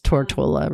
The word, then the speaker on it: Tortola: Tortuga. – Beef Island. – Oh, it's Tortola, really.